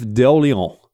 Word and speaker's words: d'Orléans: Louis-Philippe Joseph Dolan, butchered that one. I was going so well. Louis-Philippe Joseph d'Orléans. Orléans. D'Orléans. bloody hell, who would go on to become, the Duke of Orléans.